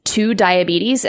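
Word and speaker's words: TuDiabetes: And then the last community app we'll talk about is Two Diabetes, and that's spelled T-U Diabetes.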